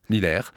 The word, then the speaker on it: Lillers: le mercredi 5 avril à Lillers, je prononce bien Lillers.